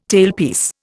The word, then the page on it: tailpiece: Indian